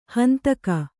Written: ಹಿಂದೆಗೆ

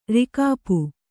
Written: ರಿಕಾಪು

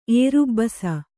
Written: ಏರುಬ್ಬಸ

